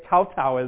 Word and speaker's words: kowtow: Maybe it'd just be a kneeling, like in China, the kuto, huh? Knock your head. How do you say it? Kowtow is the English.